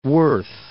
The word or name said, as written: Lake Worth